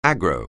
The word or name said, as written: aggro